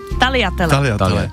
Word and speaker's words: Tagliatelle: Těstoviny, tagliatelle, nejsou to tagliatelle. – Víme, jak to je to správně teda? – Tagliatelle.